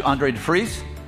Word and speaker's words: Andre de Vries: So I want to introduce you guys to Andre de Vries.